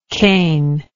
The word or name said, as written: canelike